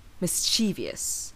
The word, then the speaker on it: mischievous: So here's how I often hear the word pronounced. Mischievous, with four syllables. Mischievous. Gwen is a very mischievous cat. However, the word actually only has three syllables. Mischievous, with the stress on the first syllable.